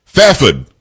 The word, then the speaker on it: Fairford: Iran demands evidence from the UK over alleged plot against RAF Fairford Air Base Fafid is what I was told how I should pronounce it from K.